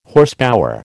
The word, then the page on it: Horsepower: American English